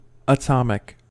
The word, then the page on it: atomic: American English